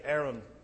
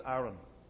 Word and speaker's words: Aaron: Now you may say Aaron, that's fine. But the proper way to pronounce it is Aaron from the Hebrew.